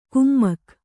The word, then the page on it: ಕುಮ್ಮಕ್: kannada